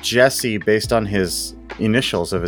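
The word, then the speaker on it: Djesse: It's supposed to have the title Jesse, which is pronounced with a silent D, is a reference to Collier's own initials.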